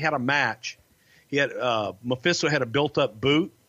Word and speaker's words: Mephisto: The great Memphisito. – The great Mephisto, Frankie Lane, man. He was a legend in your, he's from your part of the woods in Alabama. – Yeah, I just mispronounced the fuck out of it. My bad. – That's okay. It's great Mephisto.